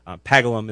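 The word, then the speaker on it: Paggleham: Pagalham is what it looks like. Pagalham is the way it's pronounced.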